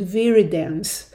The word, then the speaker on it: viridans: And there is a word for it, that they are called viridans group streptococci, VGS. Viridans is spelled V-I-R-I-D-A-N-S, and this is really a definition that is kind of not that used anymore.